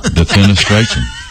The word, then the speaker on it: defenestration: T-R-A-T-I-O-N. – Pronounce that word you just spelled. – Defenestration. – Some things were in there were silent. – Yeah. – Message translation. – You said defenestration.